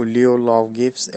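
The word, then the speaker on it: Lleu Llaw Gyffes: E aqui a gente tem aquele L duplo do galês, que, é, que eu nunca acertei a pronúncia exata. Né, mas é o, o Leo Law Gibbs. Eu falo como se fosse um LH do português, mas não é um LH, é que eu realmente não consigo fazer a pronúncia dele.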